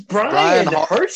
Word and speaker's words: Bryan Harsin: His name is Bryan Harsin. – Bryan Harsin, and you forgot. – Did I even say that right? Because I'd never heard of this guy before today. Oh, my. – That's right.